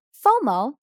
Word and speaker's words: FOMO: What does the acronym FOMO stand for? FOMO, F-O-M-O, but we pronounce it as one word, FOMO, stands for fear of missing out.